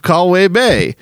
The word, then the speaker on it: Colwyn Bay: It said, Hey guys, here's a postcard from the shithole, sorry, lovely place where I live, Callway Bay. To say, oh, okay. To say it properly, it's call as in Colin and win as in win.